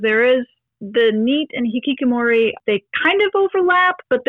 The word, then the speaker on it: hikikomori: again, make sure I'm saying this right, the Hikikomori? – Yes.